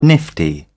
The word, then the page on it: nifty: British English